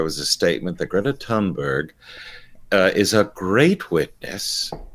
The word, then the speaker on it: Thunberg: Greta uh, Thornburg. That's not how you pronounce her name. How do you pronounce her name? Greta Thunberg.